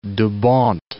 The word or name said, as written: Dubawnt